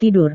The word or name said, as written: tidur